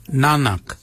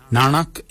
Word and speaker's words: nangag: So for neck, we say nanak, nanak. Nanak is spelled N-A-N-G-A-G. Nanak, nanak is your neck. Chin, we say yet, yet for chin. Yet is spelt Y-E-D.